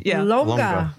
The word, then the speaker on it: lonja: Sorry if I'm mispronouncing it. For L-O-N-J-A. – Longa. – Longa? – Yeah, like there's no j in Spanish.